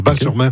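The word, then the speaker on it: Batz-sur-Mer: On prononce Bas, le T ne se prononce pas. Bas-sur-Mer. Okay.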